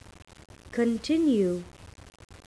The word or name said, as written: continue